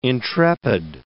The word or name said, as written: intrepid